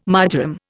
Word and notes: marjoram: Indian

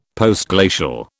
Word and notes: Postglacial: British English

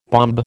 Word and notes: bombe: American English

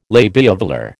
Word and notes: labiovelar: American English

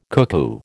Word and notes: cuckoo: American English

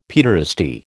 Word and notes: paederasty: American English

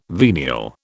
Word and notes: venial: British English

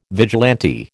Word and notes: vigilante: American English

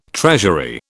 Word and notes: treasury: British English

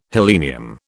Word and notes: helenium: British English

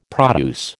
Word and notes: produce: American English

